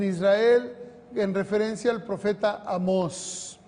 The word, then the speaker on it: Amós: No, no, Amós, tiene acento en la O.